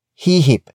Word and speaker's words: hihip: Hipan comes from the root word hihip.